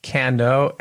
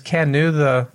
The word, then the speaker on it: Kano: I've heard it pronounced Kanu, Kano, and I think some other ways. Is Kanu what you hear most? – Yes, Kanu is the way I hear it.